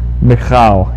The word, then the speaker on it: Michal: It was spelled M-I-C-H-A-L, and how do we pronounce that? It was Michal. Yeah, you got to get that almost.